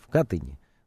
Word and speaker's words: Катыни: Но все-таки выяснение подлинных обстоятельств гибели людей в 40-м году в Катыни, все-таки более правильно ударение ставить, и в самом Смоленске-то говорят в Катыни, да? – Вы ученый, вам виднее.